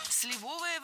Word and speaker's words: сливовое: До его дедушки Степана Николаева или Степана Николаевича, который был крепостным крестьянином и, как известно, варил очень вкусное сливовое варенье и абрикосовую пастилу. – Сливовое варенье – это ошибка. Ударение в нашем прилагательном должно падать на первый слог.